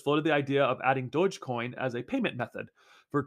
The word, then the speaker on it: Dogecoin: The price surge in Dogecoin, pronounced Dogecoin with a soft G sound, also coincides with rumors that Twitter is prototyping its own crypto wallet.